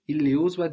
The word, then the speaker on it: iliuzwa: Nyumba hii ili uozwa juzi. – Nyumba hii iliuzwa juzi.